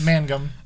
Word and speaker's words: Mangum: Jeff Magnum. – Was it Magnum or Mangum? – I'm going to look that up. – But it's Jeff. – It's Mangum. We'll call him Jeff.